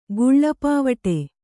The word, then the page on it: ಗುಳ್ಳಪಾವಟೆ: guḷḷa pāvaṭe